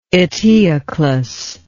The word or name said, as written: eteoclus